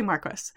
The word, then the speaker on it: Marquis: So so the way that it all kind of comes together is that Bourne, who is the Marquis, I've always pronounced it wrong, I never know how we're doing it. Let's say Marquis. – That sounds right.